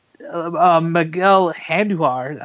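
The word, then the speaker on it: Miguel Andújar: But with the Yankees right now, Miguel Andujar, I pronounced it right.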